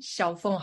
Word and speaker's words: Xiaofeng: So just search my name, Xiaofeng Harmon. It's spelled as X-I-A-O-F-E-N-G. Harmon is H-A-R-M-A-N.